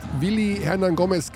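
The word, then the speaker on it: Willy Hernangómez: Und der MVP dieses Turniers ist Willi Hernan Gomez geworden. Willi Hernan Gomez. Also spricht man mit B? – Ja, mit B, aber.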